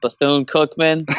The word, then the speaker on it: Bethune-Cookman: Bethune-Cookman.